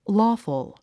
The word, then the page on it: lawful: lɔ́ːfəl; ローフル